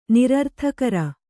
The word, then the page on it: ನಿರರ್ಥಕರ: nirarthakara